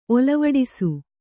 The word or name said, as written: ಒಲವಡಿಸು